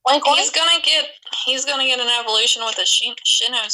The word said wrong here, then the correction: Sinnoh: I think it's Sinnoh. – How do you say it? Sinnoh.